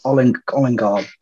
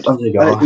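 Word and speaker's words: Ødegaard: I mean, I did what you did, Brad, and got what's Olingard, whatever. How do you pronounce it? – Odegaard.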